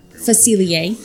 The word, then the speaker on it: Facilier: Facilier, yes, thank you.